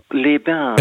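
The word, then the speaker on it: Évian-les-Bains: Evian, les bains. Habe ich das richtig ausgesprochen? – Les bains. – Les bains. – Evian, les bains.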